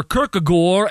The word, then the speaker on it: Kierkegaard: Soren Kierkegaard, or Kierkegaard, as my Mennonite theologian teacher used to pronounce it, was considered the father of existentialism.